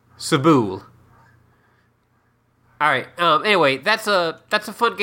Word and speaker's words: Sable: Oh, yeah, Sabelle. I played a little bit of that. – Yeah, I pronounced it like rhymed with Mabel.